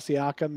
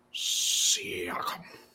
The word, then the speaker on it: Siakam: One is trade for Siakam and sign Looney. Now, Pascal Siakam. Does anybody know what Pascal Siakam makes? I did not bring that one up. – Siakam.